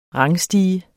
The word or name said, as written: rangstige